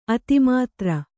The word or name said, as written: ಅತಿಮೂತ್ರ